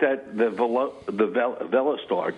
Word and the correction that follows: Veloster: Do you guys know how to say it right? – Velostar. – And it is V-E-L-O-S-T-E-R or A-R? – Yeah, Veloster. Yeah.